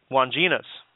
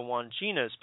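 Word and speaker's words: Wandjinas: But one of the most intriguing and perplexing legends is the Australian Aboriginal people of the that talk about the Wanginas.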